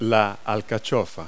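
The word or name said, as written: artichoke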